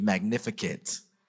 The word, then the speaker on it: Magnificat: Somebody just say it with me, Magnificat.